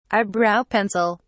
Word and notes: eyebrow pencil: British English

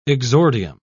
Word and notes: exordium: British English

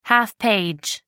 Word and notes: half page: American English

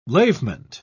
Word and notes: lavement: British English

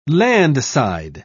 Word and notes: landside: British English

